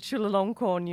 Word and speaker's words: Chulalongkorn: I actually wanted to ask you about your students, because you also teach at, I may not pronounce it correctly, Chulalongkorn University in Bangkok. – Perfectly. You pronounce great.